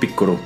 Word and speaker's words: Piccolo: También Piccolo, acá siempre le hemos dicho Piccolo, pero la manera correcta de pronunciar su nombre es Piccolo eh, también aparecía Cell Jr., que me parecía un personaje detestable.